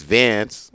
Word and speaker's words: Vance: The thing about my name is it rhymes. It's Vance. – Yeah. – It rhymes with dance.